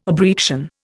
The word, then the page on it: abreaction: Indian